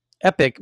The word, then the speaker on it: EPIK: So the next one is in a live Q&A, the CEO of EPIK, which is pronounced Epic, but not to be confused with the gaming company.